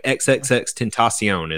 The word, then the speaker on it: XXXTentacion: XXXTentacion is how you pronounce his name.